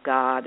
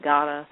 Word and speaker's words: Godde: Now, it signals to the reader that that's a combination of God and goddess. When you say it, some say that they still pronounce it God, and some say they pronounce it kind of gotta. Have you ever seen that, G-O-D-D-E? – No, I haven't.